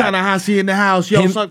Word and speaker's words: Ta-Nehisi: Tahasi? – Yeah, Coates? – I don't know how to say his name. Tallahassee? – Ta-Nehisi, yeah.